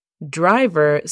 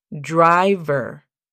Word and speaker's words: driver: La pronunciación fonética es tal y como se escribe, P-O-R-T, port. – Port. – Muy bien. La siguiente palabra, número 8, es driver. Driver significa conductor o conductora. La pronunciación fonética es D-R-A-I-V-R. Nota cómo de la V paso directamente a la R. No hay vocales. Escucha. Driver.